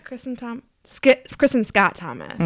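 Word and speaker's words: Kristin Scott Thomas: Yeah, Kristen Scott Thomas.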